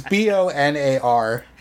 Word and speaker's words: Bonar: So Churchill and Asquith Churchill tried to plead with Asquith and the Conservative leader at that time, Bonar Law, but it's Bonar. – Yeah, it doesn't matter how it's spelled, it's for fuck's sake. Boner. His name's Bonar Law.